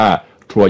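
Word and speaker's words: Troyes: พี่ไปอ่านว่าเขียนว่าทัวเยสฮะเรียบร้อย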